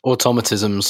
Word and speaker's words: automatisms: Automatisms. Stress on the second syllable, personally.